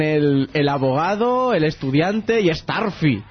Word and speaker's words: Starfy: Sí, y sí, parece ser que la pronunciación oficial es Starfy. Tiene bastante más lógica. – Sí. Uy, sí, lógica de Curro Jiménez, por lo menos. – Dios, Staffy no se llama más Staffy.